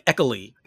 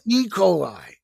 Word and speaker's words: E. coli: See, you say it right. With the way Lauer writes it, it looks like Eccoli. – E. coli. E. coli. – I'm like, dude, that's not right.